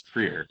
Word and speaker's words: Trier: Like French it's Trier, dude. It's Trier.